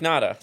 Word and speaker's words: Nada: Yeah, like Nada. Nothing, like zero, like nada.